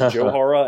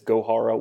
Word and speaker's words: Gohara: I comically asked the Braves for a pronunciation update on Luis Gohara, because I pronounced it Johara, and I'd heard that from a couple of people. I was wrong, they were wrong, and it is Gohara with a hard G.